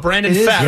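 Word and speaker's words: Pfaadt: How do we say his name? The guy that brand. He was a stud for them in the postseason last year. Come on. Help me out. How's it? – Brandon Fat. – Oh, Brandon Fat. – That is how you pronounce it.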